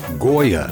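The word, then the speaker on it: Gioia: There's an article in The Atlantic by Ted Goya. Forgive me if I'm pronouncing the name wrong. It's G-I-O-I-A.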